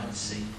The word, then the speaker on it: Monsey: It's S. Yeah. Munsey. It's like Muncie, Indiana, even though it's spelled S-E-Y instead of C, you know, with C-I-E. – I pronounce M-U-N. – M-U-N-C-I as opposed to M-O-N-S-E-Y.